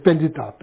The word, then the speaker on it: Pandit: So Tibetan, we say Pandita, you know, so basically probably is Pandit, that's the probably the right pronunciation. It's Sanskrit word.